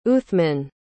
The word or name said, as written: uthman